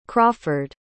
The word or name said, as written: crawford